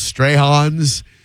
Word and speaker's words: Strachan's: If you're going to be in Clearwater, you're going to be right up the road from, it's spelled Strachan's, but it's pronounced Strahan's.